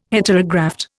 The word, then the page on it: Heterograft: Indian